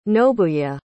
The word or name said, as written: nobuye